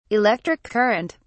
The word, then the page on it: electric current: British English